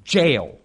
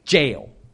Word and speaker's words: Jael: It's Jael, like you say Jael, J-A-I-L. It's Jael. But we'll get to that.